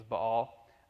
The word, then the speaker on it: Baal: And then you have the really well-known guy, Baal, or Baal, as you may have heard it pronounced, as I've always said it, but I found out the correct pronunciation was Baal.